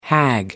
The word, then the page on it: hag: American English